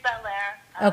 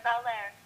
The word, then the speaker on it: Bel Air: So, of the people that have visited, and you, and I'm sorry, this might just be location or you know, what is it pronounced Bel Air or is it Bel Air? – It's Bel Air. – Okay. – But the local cobbler, like a bodily function. – Got. Gosh. I don't want to say it wrong. – Yeah, it's just like Bel Air, just like the Fresh Prince, for real.